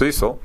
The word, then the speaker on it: CECL: Some medium-sized banks are fighting the new upfront accounting method known as CECL, short for Current Expected Credit Losses, and pronounced CECL, that is intended to make banks more transparent.